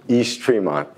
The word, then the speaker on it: East Tremont: East Tremont, yeah.